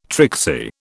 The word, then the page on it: tricksy: British English